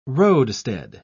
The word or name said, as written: roadstead